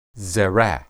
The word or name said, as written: zera